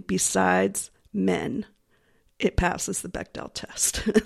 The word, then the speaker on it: Bechdel: I can never pronounce it correctly. The Bechdel test? – Oh, the Bechdel test.